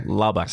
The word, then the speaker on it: Lubbock: You're giving it a tick at the end. – Yeah. – Which I think, oh, it's Lubbock. – Okay. – Lubbock. – See, there we go. That's fine. Yeah. – So you've just been saying it wrong the whole time. – Yeah. You've got the ick in there, which I think is a bad thing.